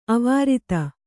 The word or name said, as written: ಅವಿರೋಧ